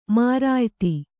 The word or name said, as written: ಮರ್ಯಾದೆ